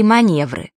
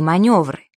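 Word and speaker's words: манёвры: Если бы ее писали, то не было бы таких слов-уродцев, как «свекла», «планер» и «маневры». Ведь правильно «свекла», «планер» и «маневры».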